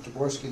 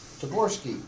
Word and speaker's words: Taborsky: My name is Carl Frank Taborski II, spelled K-A-R-E-L. Frank is a normal people spell. Taborski, T-A-B-O-R-S-K-Y.